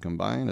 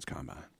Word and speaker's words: combine: Remember, I'll remind you again, in football, emphasis on the wrong syllable. It's not offense, defense, and combine. It's offense, defense, combine.